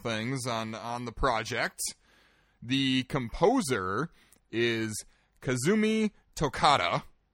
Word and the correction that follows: Totaka: Oh, wait, nope. Hang on, I said that wrong. Kazumi Totaka. – Totaka.